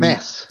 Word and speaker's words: MAS: How do I say this? MAS, is it Mass? The um, Mass. Yeah, yeah Mass. Mass.